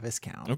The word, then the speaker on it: Viscount: The Viscount? Like a discount? – Yeah, like it rhymes with discount, Viscount.